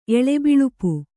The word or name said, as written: ಎಳೆಬಿಳುಪು